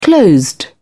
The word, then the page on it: closed: British English